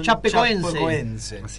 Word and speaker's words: Chapecoense: Chapecoense.